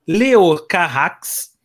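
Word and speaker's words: Leos Carax: Ele é o, dirigido por este cara aqui, que, cuja, é, é, ele é francês, cuja pronúncia é Leo Carrax, o Leo Carrax é um dos cineastas franceses mais, digamos, é, estranhos, que apela para filmes estranhos e estranheza.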